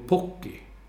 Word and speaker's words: Pukki: uttalade jag då Pukki fel. Han sa U blir O på finska. Så då ska man säga Teemu Pukki.